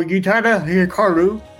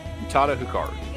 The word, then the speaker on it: Utada Hikaru: So, Utada Hikaru. Did I say it right? – Utada? – Yes. Okay. – Utada Hikaru.